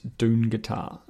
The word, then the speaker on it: Dungatar: She starts a new committee with Elspeth called the Dungatar Social Club, but they pronounce it Dungatar, again, avoiding the phrase dung.